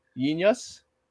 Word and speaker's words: jeen-yuhs: Which is it's spelled JEEN-YUHS. So Yin Yus.